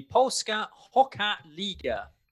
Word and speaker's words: Polska Hockey Liga: It is the Polska Hockey Liga. – Okay. – Is how it is pronounced.